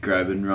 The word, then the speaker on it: Groban: She'd shatter like a Josh Groban CD. Groban rhymes with Logan.